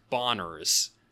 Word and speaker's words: Bonerz: and the whole time it was Bonners. – Yes, it is spelled B-O-N-E-R-Z, but, you know, maybe it was out in the universe.